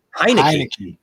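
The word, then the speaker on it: Heinicke: Hinsky? Heineke. I love a good cold Heineken. Heineke.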